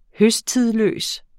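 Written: høsttidløs

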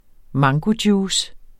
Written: mangojuice

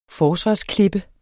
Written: forsvarsklippe

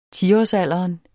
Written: tiårsalderen

